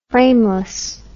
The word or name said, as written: frameless